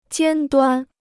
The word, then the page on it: 尖端: jiān duān